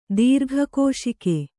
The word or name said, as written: ದೀರ್ಘಕೋಶಿಕೆ